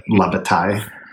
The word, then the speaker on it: La Bataille: I should know this because we played I would wonder if the same guy was in the La Bataille. Did I say that right? Bataille de Moscow? – Yeah, I think after years of saying it, we finally determined that is the correct way to say it. La Bataille.